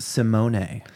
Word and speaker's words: Simone: I found out recently her uh-oh. Name uh-oh. Is actually pronounced Simone.